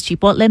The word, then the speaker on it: Chipotle: One of them is Chipotle Mexican Grill.